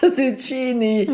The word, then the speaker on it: Zucchini: CCH spricht man immer als K, denn das H ist dafür da, um aus dem Tsch ein K zu machen. – Deswegen heißt es Cappuccino mit zwei C, aber Zucchini.